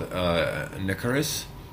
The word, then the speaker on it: Nicarus: Well, next up we have a new song by a band called Nicaris. I believe that's what it's pronounced. So it's NICARUS. So. – Yeah, that sounds right.